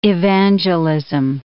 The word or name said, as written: evangelism